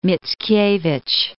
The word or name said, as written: Mickiewicz